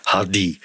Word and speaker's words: Hardy: She does like her Hardy pronounced like that.